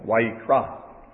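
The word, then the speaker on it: Vayikra (ויקרא): It's Vayikra. Or I think the accent's on the last syllable, Vayikra.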